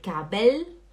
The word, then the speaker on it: Gabel: Por favor, o L é L, L, L, e não U, não é Gabel, não é Löffel, não é Tzetel, é Gabel, Löffel, Tzetel, hum?